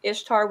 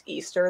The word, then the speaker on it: Ishtar: Ishtar was not pronounced Easter. There's zero evidence to say that that was ever a pronunciation for that.